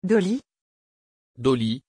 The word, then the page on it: Doli: French